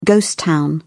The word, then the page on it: ghost town: British English